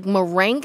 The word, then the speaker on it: merengue: The last syllable is spelled G-U-E. – Is it like merengue? – Merengue. – Oh, merengue. – Good job.